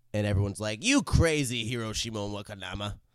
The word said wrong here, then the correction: Hiroshi Yamauchi: Hiroshi Yamauchi. – Hiroshi Yamauchi.